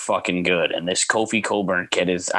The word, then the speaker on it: Cockburn: Yeah, because it's spelled Cockburn. I know. – I forgot about that. When I heard the announcer say it, I was like, do they just have like a deal with ESPN that say his real name or something? Because there's no fucking way. There's no way, no pronunciation, no culture, no ever that is pronounced Coburn. – C-O-C-K-B-U-R-N. Yeah. Yeah. – R-O-C-K.